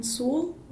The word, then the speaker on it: Sewell: My name's Allison Sewell. Last name is S-E-W-E-L-L.